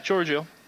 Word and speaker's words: Giorgio: Giorgio Chiellini, is that how you pronounce the first name? – Yeah, Giorgio.